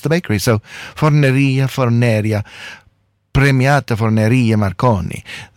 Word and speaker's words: Premiata Forneria Marconi: And I believe I've been pronouncing the name of the band incorrectly. It is not Premiata Forneria Marconi, as I've been saying. It's Premiata Forneria Marconi.